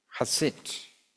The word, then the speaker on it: chesed: He said, I don't know if I've said it right, H-E-S-E-D, the Hebrew word. Chesed. Chesed. Chesed. It's a H, but it's an H.